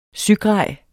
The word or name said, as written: sygrej